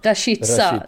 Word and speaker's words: Rashica: jag vet aldrig om jag ska uttala det, Rashica eller Rashica. – Rashica väl? – Rashica. Så säger man i Sverige, det gör man inte kommentatorerna här, så man blir ju förvirrad.